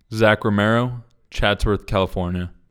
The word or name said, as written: Zach Romero